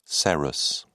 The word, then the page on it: serous: British English